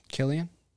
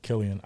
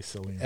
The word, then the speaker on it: Cillian: Killian? – Yeah, Killian. I don't know why I always say Cillian. – That's how I pronounced it for a while, until I heard it pronounced properly.